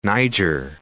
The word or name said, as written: Niger